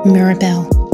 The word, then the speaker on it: Mirabelle: Mirabelle. It sounds like miracle.